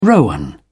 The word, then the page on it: rowan: British English